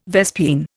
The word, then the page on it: vespine: Indian